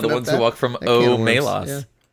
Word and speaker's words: Omelas: And to start us off, technically I think it's pronounced Omelas, according to Le Guin,